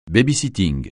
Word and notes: baby-sitter: francais

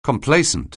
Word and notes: complacent: anglais

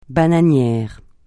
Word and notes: bananier: francais

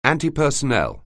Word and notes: antipersonnel: anglais

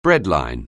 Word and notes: breadline: anglais

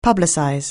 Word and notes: publicize: anglais

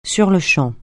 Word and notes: sur-le-champ: French

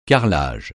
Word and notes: carrelage: francais

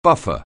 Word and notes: buffer: anglais

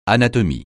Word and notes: anatomie: francais

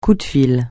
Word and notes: coup de fil: French

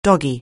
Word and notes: doggy: anglais

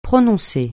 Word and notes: prononcer: francais

